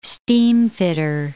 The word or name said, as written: steamfitter